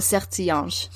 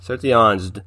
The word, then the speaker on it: Sertilanges: Is it French? – It's French. – So Sertilanges. – Sertilanges, Dominican priest.